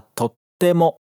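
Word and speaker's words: Tottemo (とっても): Atenção para a pronúncia aqui, que ele fala com aquele vazio de pronúncia entre o to e o te. Escuta. Tottemo. Viu? Não é tottemo, é tottemo.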